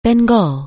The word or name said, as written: Bengal